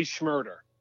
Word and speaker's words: Shmurda: There's a uh, there's a there's a rapper who was big about five years ago, and his name was Bobby Shmurda. and he got I know rhymes with murder.